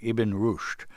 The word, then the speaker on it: Ibn Rushd: The story actually begins, eight centuries ago with a 12th-century philosopher named, I hope I pronounced this correctly, Ibn Rushd. – Yes.